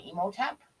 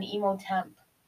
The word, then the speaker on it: Imhotep: Instead of going into as much of the lore on Imhotep, yep.